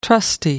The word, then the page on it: trusty: American English